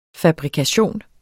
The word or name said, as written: fabrikation